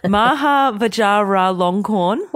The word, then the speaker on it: Maha Vajiralongkorn: The king, and I'm going to try and pronounce his name, Maha Vajiralongkorn.